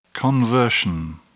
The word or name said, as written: conversion